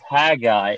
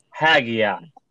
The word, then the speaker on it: Haggai: Folks in the audience, it is not pronounced Haggai. It is pronounced Haggai.